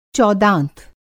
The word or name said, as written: चौदाँत